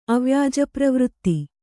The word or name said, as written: ಅವ್ಯಾಜಪ್ರವೃತ್ತಿ